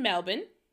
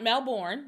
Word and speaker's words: Melbourne: So first, this all takes place in Melbourne. Not Melbourne, Melbourne. That's how they say it in Australia.